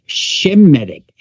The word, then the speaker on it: Shemitic: Okay, first of all, we don't pronounce it according to its original sound. It's Shemitic, S-H-E-M-I-T-I-C.